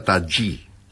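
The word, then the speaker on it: Tajy: Se escribe Tají con J, Y, pero me han dicho que se pronuncia Tají.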